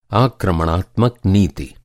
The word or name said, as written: आक्रमणात्मक